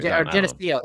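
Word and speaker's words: Gnesio: Anyway, Genesio, that's it.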